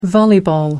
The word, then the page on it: volleyball: American English